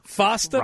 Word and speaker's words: FOSTA: FOSTA rhymes with pasta.